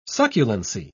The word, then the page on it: succulency: British English